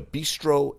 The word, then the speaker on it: Bistreaux: And then they, earlier today, had the Walk-On Independence Bowl, which Walk-On is a bistro and bar. But it's spelled B-I-S-T-R-E-A-U-X. Bistro. Bistro.